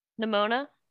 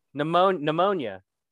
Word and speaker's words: Nimona: Nimona? – Pneumonia. – Nimona. Not pneumonia.